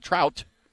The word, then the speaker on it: Traute: It seems that Demetrius Sopolis, 29, and his former beauty queen wife, Trout, how do you say that? T-R-A-U-T-E, wanted a child badly, but Demetrius was told by a doctor that he was sterile.